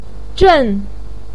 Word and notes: zhen: 4

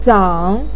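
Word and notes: zang: tone 3

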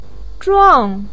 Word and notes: zhuang: 4

